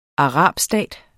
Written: arabstat

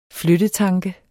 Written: flyttetanke

